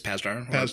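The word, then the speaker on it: Pasdar: But then we got Adrian Pazdar? God damn it, I can't pronounce that. – I think it's Pazdar. Pazdar, yeah.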